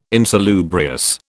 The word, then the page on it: insalubrious: British English